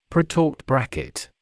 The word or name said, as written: pretorqued bracket